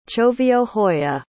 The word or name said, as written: choviohoya